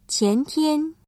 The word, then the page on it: 前天: qiántiān